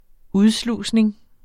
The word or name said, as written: udslusning